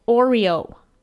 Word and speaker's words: Oreo: Last week, Todd Takei at Tooth Takei said, when me, says Oreo, I hear Oriole.